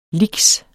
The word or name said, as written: lix